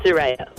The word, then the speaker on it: Suraya: We pronounce it Soraya. – Soraya. – S-U-R-A-Y-A.